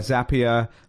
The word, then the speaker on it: Zapier: You've obviously been acquired by Zapier, rhymes with happier.